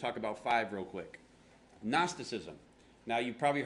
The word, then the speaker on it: Gnosticism: Yeah, the G is silent. I don't know why it's like that, but I didn't make the word, right? But Gnosticism.